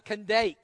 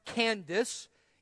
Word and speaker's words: Kandake: On his way, he met an Ethiopian eunuch, an important official in the charge of all the treasury of the Kandake. That language there, if you heard a few moments ago, is sometimes pronounced Candace. It's a title.